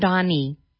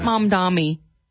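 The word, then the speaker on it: Mamdani: Mondami. Not Mom Dami.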